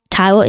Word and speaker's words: Taiwo: Taiwo is spelled T-A-I-W-O.